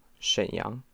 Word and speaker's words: Shenyang: Shenyang. – Shenyang. – Okay. – Is that right? – Mm-hmm. – Probably sucked. – Yeah. – You just want to be nice. Shenyang.